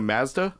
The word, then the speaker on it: Mazda: Oh, you mean a Mazda? – Mazda. – Yeah, it's pronounced yeah. Mazda.